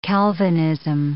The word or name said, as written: Calvinism